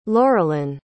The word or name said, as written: laurelin